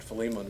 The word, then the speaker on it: Philemon: He wrote the book of Colossians, which we had just finished studying, and he wrote a small book called Philemon, or Philemon, however you want to pronounce it. Philemon is the way I pronounce it.